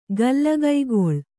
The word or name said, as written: ಗಲ್ಲಗೈಗೊಳ್